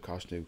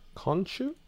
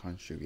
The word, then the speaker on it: Khonshu: I picked up Avengers kind of versus Moon Knight type of stuff, Age of Khonshu, and it was a run last year. – Khonshu? – I always pronounce it wrong. Khonshu, yeah.